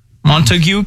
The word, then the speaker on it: Montague: People said I had a very Shakespearean pronunciation of the town Montague. – Oh, well, is it not? Oh. That's not how it's called. It's how it's spelled.